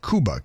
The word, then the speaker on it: Kuba: Jonathan, out in Phoenix, there's a guy named Michael, I believe the last name's pronounced Kuba, K-U-B-A.